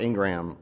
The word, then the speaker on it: Ingraham: Well, did you see Laura Ingraham? Am I pronouncing her name right? – Yeah.